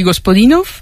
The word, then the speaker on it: Gospodinov: Ayúdanos a pronunciar los autores. – A ver, Georgi Gospodinov, Zdravka Eftimova y Vera Mutavcheva. – Ahora sí, ahora sí.